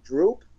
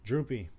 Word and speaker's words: Droopy: How do you say his name? Droop? – Droopy.